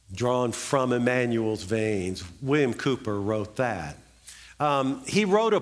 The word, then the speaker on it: Cowper: And so William Cooper, it's actually spelled C-O-W-E-R or C-O-W-P-E-R, but it's Cooper is how it's pronounced.